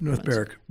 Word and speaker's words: North Berwick: Berwick, am I saying it right? I think I looked up. – North Berwick. – Berwick. So it's spelled with a W, not really pronounced that way.